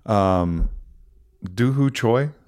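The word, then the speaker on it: Doo Ho Choi: That was so, Doohoo Choi, is that how you pronounce his name? – Yeah.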